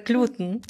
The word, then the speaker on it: Gluten: Das ist, im Duden steht, soweit ich weiß, Gluten, aus dem Grund, dass sehr viele wissenschaftliche Begriffe auf diese betonte letzte Silbe enden.